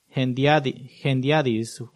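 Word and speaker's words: hendiadys: Es una palabra grande que se dice gendiadis. ¡Wow! ¿Cómo se deletrea eso? Y para aquellos que toman nota, se escribe H-E-N-D-I-A-D-Y-C. H-E-N-D-I-A-D-Y-S.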